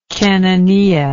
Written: cananea

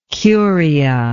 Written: curia

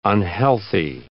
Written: unhealthy

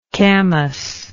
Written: camass

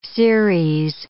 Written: Ceres

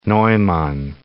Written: Neumann

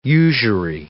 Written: usury

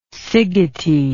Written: szigeti

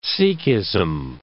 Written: Sikhism

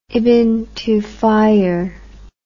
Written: ibn tufayr

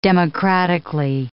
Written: democratically

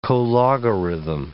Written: cologarithm

